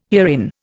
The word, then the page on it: herein: Indian